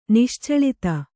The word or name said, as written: ನಿಶ್ಚಳಿತ